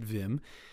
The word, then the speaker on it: Wim: Wings of Desire is directed by Wim Wenders, a German director who, in English, we might pronounce his name Wim Wenders, actually, but uh, he's German, so I'm going to pronounce it Wim.